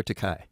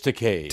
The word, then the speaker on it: Takei: Actually, is it Takei or Takai? It's Takei. – Takei. Rhymes with okay.